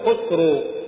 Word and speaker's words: خسرو: مثلا الفرس كانوا يقولون خسرو خسرو، ملك الفرس يقال له خسرو. العرب لا يمكن أن تنطق يعني حرفا أو حرف مضموم أو واو قبل حرف مضموم، فلذلك قلبوه، وكذلك الخاء تثقلوها فقلبوها إلى كسرى. انظروا كيف غيروا العرب خسروا إلى كسرى.